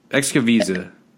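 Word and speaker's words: Xocoveza: It's called – well, it starts with an X, and I never know how to pronounce it. So it's Excoviza.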